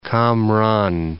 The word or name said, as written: Cam Ranh Bay